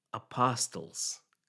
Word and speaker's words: apostle: And of course, when we spoke, he had a very substantive suggestion, which is, he said, I don't think you pronounced apostles correctly. It's a silent T, apostle, right?